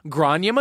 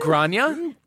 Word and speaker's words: Gráinne: Is it G-R-A-I-N-N-E? – Exactly. – Yes, it is, Sarah. – It's beautiful. – Wow. – Exactly how it's pronounced.